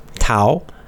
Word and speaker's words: Tao: And sometimes you'll see it spelled with a D, although less often you'll see it's D-A-O. But it's always pronounced duh with a D sound. So it's very confusing. It's spelled with a T, T-A-O, but then we say Tao.